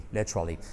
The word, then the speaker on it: literally: Por cierto, pronunciación, letra, qué buena letra, y luego Lee, como Bruce Lee, literally.